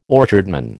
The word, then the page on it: orchardman: American English